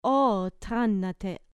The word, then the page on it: o thanate: grec